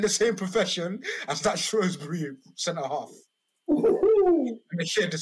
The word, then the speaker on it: Shrewsbury: It spells Shrewsbury, pronounced Shrewsbury. – Shrewsbury. – There you go.